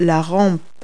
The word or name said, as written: handrail